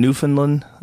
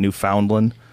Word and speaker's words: Newfoundland: I was in Newfoundland. I know how to say it now because people got mad at me because I said, "Oh, we're calling it Newfoundland." I guess it's Newfoundland.